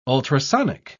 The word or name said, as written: ultrasonic